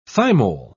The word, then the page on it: thymol: British English